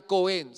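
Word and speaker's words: Kohen: es la palabra griega Cohen, se escribe con H, K-O-H-E-N, que significa aquel que se acerca a la presencia divina.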